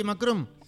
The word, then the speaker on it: Macron: ¿Se dice así, Macron? Sí.